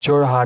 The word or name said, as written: Jorhat